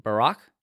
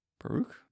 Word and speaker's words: Baruch: They say that this portion of Enoch draws from either Fourth Ezra or from, not either, but from Fourth Ezra and Second Baruch. Is that right? How you say his name? – Baruch. – Baruch. – Yeah.